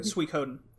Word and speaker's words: Suikoden: And I really want to try a Suikoden? Suikoden? – Suikoden. – Okay, I never knew how to say it.